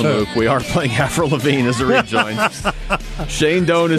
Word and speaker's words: Avril Lavigne: This is Avril Lavigne. – Avril Lavigne.